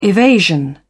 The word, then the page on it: evasion: British English